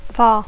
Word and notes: far: English

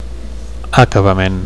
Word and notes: acabament: Catalan

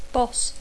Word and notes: boss: English

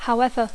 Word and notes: however: English

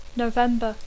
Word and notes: November: English

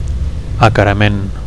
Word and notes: acarament: Catalan

